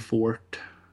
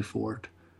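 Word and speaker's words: Forte: Ed Helms är med och Will Fort. – Mm. Jag tror man uttalar Will Fort. Han stavas F-O-R-T-E i alla fall.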